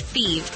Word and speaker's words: Fives: Thieves is spelled F-I-V-E-S, like the number, but pronounced Thieves.